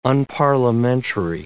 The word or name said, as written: unparliamentary